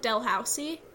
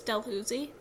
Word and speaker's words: Dalhousie: Because it's spelled like Dalhousie, but it's Dalhousie.